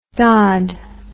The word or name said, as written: dod